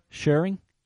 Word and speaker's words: Scheuring: S-C-H-E-U-R-I-N-G. He's a Scheuring. Ian Scheuring, I'm going to go with.